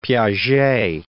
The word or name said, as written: Piaget